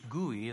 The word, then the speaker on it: Gui: Guy is how he pronounced his name, but actually it was from Chinese. Gui is how he pronounced his name.